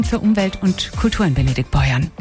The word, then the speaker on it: Benediktbeuern: Hier bei mir sind Pater Heinz Menz, Direktor der Mitbrüdergemeinschaft der Salesianer Don Boscos im Kloster Benediktbeuern.